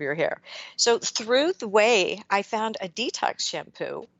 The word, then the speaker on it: OUAI: You can find out more and they have a whole line of body products as well at The OUAI, which is spelled T H E O U A I dot com and use code WISE Health for 15% off your entire purchase.